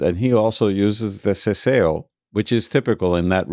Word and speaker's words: seseo: Next, we'll hear Paulina pronounce the same words with seseo, which is spelled S E S E O.